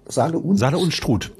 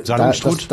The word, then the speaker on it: Saale-Unstrut: Doch, ich glaube, Saale-Unstrut, da wie spricht man das aus? – Saale-Unstrut. – Ja, danke. – Saale-Unstrut.